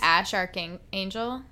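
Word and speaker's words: X Æ A-Xii: That's how that's that name is pronounced. It's Ash Archangel.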